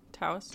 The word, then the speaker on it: Taos: How is it spelled? – T-A-O-S. – Oh, yeah. Taos.